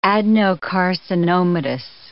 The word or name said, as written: adenocarcinomatous